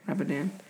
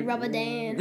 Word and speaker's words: Ramadan: How do I say it? – Ramadan? Ramadan. – Ramadan.